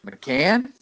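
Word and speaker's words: McCaughan: McCoffin? Is that nope. – right? – Nope. – How do you say it? – I believe it's just McCann. – McCann? – Yeah, I think it's Mac McCann. I know. No, I said it wrong my whole life.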